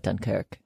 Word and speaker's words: Dunkirk: Also note Dunkirk. Even in the trailer, they call it Dunkirk, with the emphasis on the Kirk.